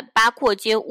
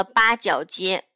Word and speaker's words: 八廓街: 但是由于呢，拉萨大概有三分之二差不多都是四川人，然后在四川话中呢，廓与角的发音又非常的相近，所以呢，他们就把八廓街误读成了八角街，慢慢的流传成八角街了。